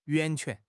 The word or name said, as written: 源泉